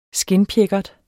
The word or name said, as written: skindpjækkert